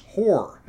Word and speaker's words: Hoare: It was the work of House Hoare, and I really do think that's how it's pronounced, despite the fact it's spelled H-O-A-R-E.